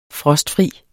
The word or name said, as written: frostfri